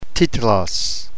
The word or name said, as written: titlos